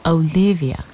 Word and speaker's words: Olivia: Olivia. – He repeats it, with the emphasis on the second syllable, as though he's feeling out the word in his mouth.